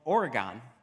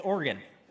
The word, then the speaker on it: Oregon: One of them is the state I formerly called Oregon. Apparently it's Oregon.